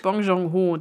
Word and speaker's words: Bong Joon-ho: Das ist natürlich Bong Joon-ho, der Typ, der auch Parasite gemacht hat. Der hat vor sieben Jahren Snowpiercer gemacht. Und äh, bevor Bong Joon-ho Snowpiercer gemacht hat, gab es den Stoff schon.